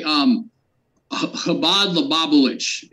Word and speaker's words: Chabad Lubavitch: Really quick, can I just say, Chabad Lubavitch is how you say that.